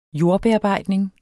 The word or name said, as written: jordbearbejdning